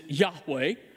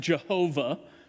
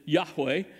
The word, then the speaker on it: Yahweh: we say it out loud, is Yahweh, mispronounced Jehovah, but proper pronunciation is Yahweh.